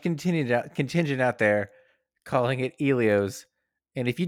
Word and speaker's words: Elio's: Is it Elio's or Elio's? – It's Elio's, end of story.